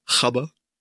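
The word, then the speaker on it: Gabber: Die Doku heißt Die neuen Gabba Hardcore Never Dies oder wie es die Holländer so schön aussprechen, Chabba.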